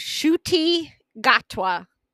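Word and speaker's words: Ncuti Gatwa: And I have looked him up on Wikipedia to get the correct pronunciation of his name, because I would not be able to pronounce it. It is Shuti Gatwa.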